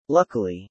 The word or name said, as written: Luckily